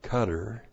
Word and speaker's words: Qatar: In Qatar, as some people pronounce it, Qatar, in Qatar and Egypt, far from the fighting in Gaza, Hamas leaders live in luxury.